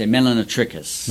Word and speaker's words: Melanotrichus: But we usually say Melanotrichus.